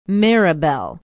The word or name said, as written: maribel